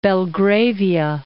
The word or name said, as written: Belgravia